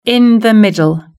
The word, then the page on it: in the middle: British English